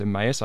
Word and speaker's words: Emmaus: Some people don't know how to pronounce Emmaus. I don't think I'm pronouncing it right. But no, you're doing fine.